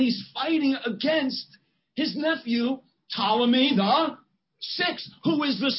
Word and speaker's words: Ptolemy: Now, Ptolemy is spelled with a P, like Ptolemy, but the P is silent.